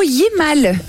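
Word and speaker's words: hiémal: On est sur le mot Yémal. Alors, c'est pas j'ai mal.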